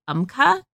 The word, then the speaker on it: Umcka: And it's one that I use called Umca. – It's spelled, yes, you swear by this. – I do. It's spelled U-M-C-K-A.